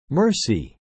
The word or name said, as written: mercy